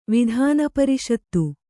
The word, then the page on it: ವಿಧಾನಪರಿಷತ್ತು: vidhāna pariṣattu